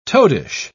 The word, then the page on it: toadish: British English